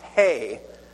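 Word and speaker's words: hey (ה): Now, it's not pronounced H. It looks like an H. But in Hebrew, it's pronounced hey, like H-E-Y, hey.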